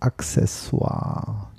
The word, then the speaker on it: Accessoire: Das Wort Accessoire ist auch ziemlich fies. Wir erklären das einmal. Es heißt Accessoire. Genau. Verband also die Aussprache Accessoire schnell aus eurem Kopf.